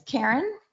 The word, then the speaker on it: Karin: It's Karen, K-A-R-I-N. Hempkes, H-E-M-K-E-S, and it's 1 Crestmont.